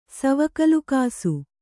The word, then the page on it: ಸವಕಲುಕಾಸು: kannada